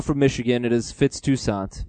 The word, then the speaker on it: Toussaint: or Toussaint or Toussaint, however you want to pronounce it. – Toussaint. – Because I'm not sure. – It's Toussaint.